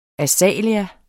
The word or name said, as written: AZALEA